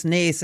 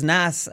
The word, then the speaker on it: Naas: A little birdie told me that you pronounce Naas as Nass, here on one of the shows before, which is probably the worst pronunciation I've ever heard, which probably outdoes any terrible pronunciation I've ever done.